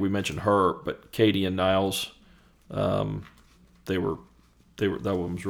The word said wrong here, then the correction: Niels: That's your first pronunciation. I think it's Niels.